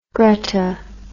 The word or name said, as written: gretta